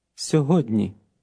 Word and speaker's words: сьогодні: Here's the word today. It has a DN combination in the middle. Listen and repeat. – Сьогодні.